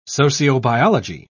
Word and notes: sociobiology: British English

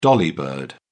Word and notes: dolly bird: British English

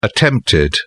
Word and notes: attempted: British English

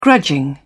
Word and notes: grudging: British English

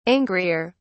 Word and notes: angrier: British English

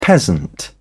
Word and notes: peasant: British English

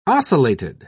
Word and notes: ocellated: British English

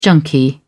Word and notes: junkie: British English

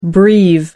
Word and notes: breve: American English

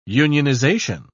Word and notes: unionisation: British English

